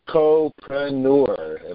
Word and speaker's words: chocopreneur: He's a chocopreneur. Am I saying that right, David? – Yes. Just take the word chocolate and entrepreneur and glue them together.